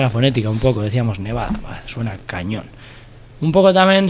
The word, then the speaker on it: Nevadah: Es igual, se llama Nevada tal cual. – Nevada.